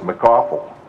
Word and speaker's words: McAuliffe: I once had a boss who taught me the correct way to pronounce that was McAuliffe,